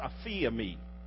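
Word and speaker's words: aphiemi (ἀφίημι): It's a little word we pronounce, aphiemi.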